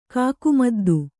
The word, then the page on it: ಕಾಕುಮದ್ದು: kannada